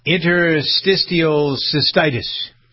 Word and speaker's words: interstitial cystitis: Interstitial cystitis.